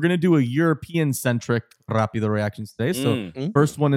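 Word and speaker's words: rápido: We're doing a little rapido reaction. – All right. Okay. You got to roll your R's if you want to be a part of this segment.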